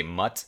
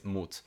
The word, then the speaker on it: Mut: Mut, I think it's spelled like, I guess, in English, I guess you would say Mut. But I think it's in German, it's Mut, which means courage.